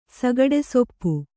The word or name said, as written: ಸಗಡೆಸೊಪ್ಪು